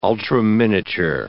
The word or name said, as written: ultraminiature